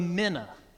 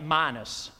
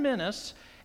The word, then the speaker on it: mina: Luke chapter 19, we have what's called the parable of the mina. I've been calling it minus my whole life, and then I just found out this week, that's not how you pronounce the word.